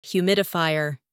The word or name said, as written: humidifier